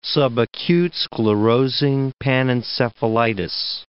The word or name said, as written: subacute sclerosing panencephalitis